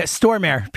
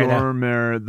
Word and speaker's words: Stormare: Stormare. Peter Stormare. Or Stormer, whatever it is. There's a T in there. – Stormare.